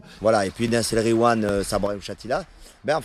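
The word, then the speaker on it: Nass El Ghiwane: Donc la chanson de Nas El Gwiam, je vais mal prononcer. – Nas El Gwiam. Voilà. Et quand il y a GH, tu dis re. Tu fais comme si c'était un R.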